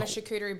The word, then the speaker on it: charcuterie: I do like a nice charcuterie board. You know what one of those is, obviously, Dane. So yeah. Is that how you say it? – Charcuterie. – Yeah. – Yes. Charcuterie.